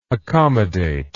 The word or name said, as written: accommodable